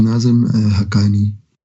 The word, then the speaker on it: Nazim Haqqani: His name is Maulana Sheikh Nazim Haqqani.